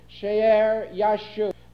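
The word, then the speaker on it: Shear-jashub: We may pronounce it She'er Yashub.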